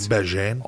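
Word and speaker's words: Bagent: This Bajent kid or Bagans or however the fuck you pronounce it. – Bajent.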